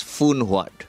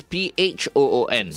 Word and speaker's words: Phun Huat: You know, all these years I've been pronouncing this as Phun Huat. – Okay. – Because it's Phoon. – So how do you say like a typhoon? – How do you say Phuket?